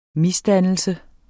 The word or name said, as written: MISDANNELSEN